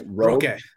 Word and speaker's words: Roque: Actually, somebody posted in the WhatsApp group here about one of the players, about Vitor Roque. I don't know if I'm saying that right. – Roque.